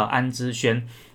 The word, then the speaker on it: 安之儇: 然后把那个错字边，就是那个部首那个错字边去掉，然后呢加上了人字边，那这个部首一改变呢，它的发音就会变从环变成轩哈，所以它应该叫安之轩。